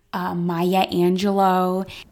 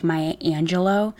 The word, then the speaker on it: Maya Angelou: Did you purposely mispronounce Maya Angelou's name?" And I'm like, "Oh my God, what did I say?" And she's like, "Maya Angelou." So I'm sorry. I meant Maya Angelou.